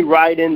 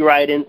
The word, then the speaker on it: Riden: And I'm on Facebook, Jeremy Ryden. The way you spell that last name is R-I-D-E-N, Jeremy Ryden.